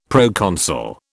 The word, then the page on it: proconsul: British English